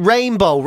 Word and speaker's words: Reinbeaux: This one, Rainbow, right? Except it's spelled R-E-I-N-B-E-A-U-X. – Rainbow. – So it sounds like a D4 person saying rainbow.